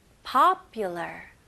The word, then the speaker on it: popular: Okay, 발음 연습해 볼게요. popular. popular. Okay, 강세는 앞부분에